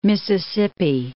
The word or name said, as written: Mississippi